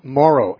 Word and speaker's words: Mauro: Morrow, M-A-U-R-O.